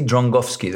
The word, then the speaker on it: Drągowski: I don't know where the N comes from, because if there was an accent somewhere, I'll say, okay, if there's an accent on the G, it means the G is Ng. But there's nothing. It's just Drągowski, and for some reason, there's an N somewhere.